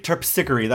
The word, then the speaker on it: Terpsichore: She plays Terpsichore, or is it Terpsichore? Terpsichore. That's how she pronounces it.